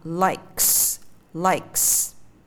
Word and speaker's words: likes: khi mình thêm sờ vào mình đọc đó là starts starts hay là like like âm cuối là âm k, là một âm không rung mình đọc là likes likes